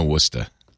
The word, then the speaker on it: Worcester: Worcester, Massachusetts.